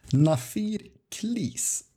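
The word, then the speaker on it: Na Fir Chlis: Och de heter Nafir Klis. Eh, ursäkta mitt uttal, men ungefär så ska det sägas.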